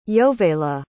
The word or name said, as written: Yovela